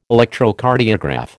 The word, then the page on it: Electrocardiograph: American English